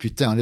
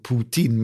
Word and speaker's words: Putin: Men för det roliga är att om man uttalar Putin så som det stavas på franska, så säger det putain, det betyder ju hora. Men därför säger de Putin med P-O-U-T-I-N-E.